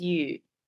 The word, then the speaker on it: Eu: Oh, Cantonese is Yu. – Yu, as in YU in Cantonese, what we normally see, is it? Or Yi? – Maybe. I mean, or YII? – You the pronunciation is just you, so like, but yeah, we write it as EU, yeah.